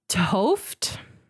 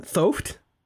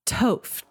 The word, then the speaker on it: 't Hooft: Okay, it is pronounced Toft. – Okay. – But it is spelled Thoft. – Mm-hmm. – T-H-O-O-F-T. – Okay. Say it again. Thoft? – Toft.